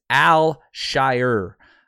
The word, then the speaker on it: Al Shire: UH, if you look at the pronunciation, Azeez, and it's Al Shire um, Al Shire. So, like, if you think about like the Hobbits, right? The Shire, uh, Al Shire.